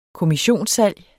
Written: kommissionssalg

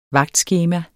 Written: vagtskema

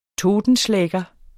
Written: totenschlæger

